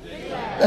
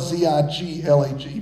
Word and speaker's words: Ziklag: Some translations spell it Z I K L A G. Some spell it Z I G L A G. But either way, Ziglag.